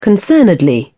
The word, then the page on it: concernedly: British English